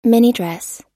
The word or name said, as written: minidress